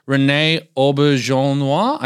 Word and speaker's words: René Auberjonois: By the way, the person who plays Anne Hathaway's father, I mentioned earlier, René Auberjonois, I think is how you pronounce his name. – I think you've crushed that in one.